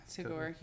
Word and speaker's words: Tagore: Tagore. Am I saying it right? Is it Tagore? Yeah, yeah, Tagore here.